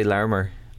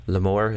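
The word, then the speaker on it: Larmour: Jamie McCauley, you may as well cover the correct pronunciation of Larmer. You've me awful confused. Right? – I would say Larmer. – Larmer. – I'm going with Larmer. There you go. – Lamour, is that what some people are saying? – I've heard Larmer.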